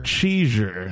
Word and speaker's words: cheezure: Cheesure. Now that's spelled C-H-E-E-Z-U-R-E. Cheesure. And it's the combination of cheese and seizure.